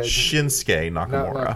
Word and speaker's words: Shinsuke Nakamura: Shinsuke. You said like Shunosuke or some. You put like four extra syllables in there. – What he said. – Shinsuke Nakamura.